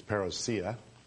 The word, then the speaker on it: parousia: They don't even pronounce the word right. It's parousia.